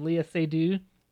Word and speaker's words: Lea Seydoux: Lea Seydoux? – Oh, yeah.